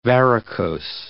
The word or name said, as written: varicose